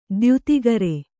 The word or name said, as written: ದ್ಯುತಿಗರೆ